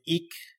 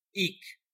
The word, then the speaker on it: Eek: We call him Eek. According to Andy Tremblay, that's because the G is silent.